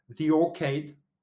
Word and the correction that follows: Orchard: Orchard. ok, d'accord. Donc, je l'ai mal prononcé.